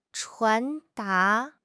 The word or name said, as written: 传达